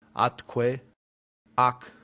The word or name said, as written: atque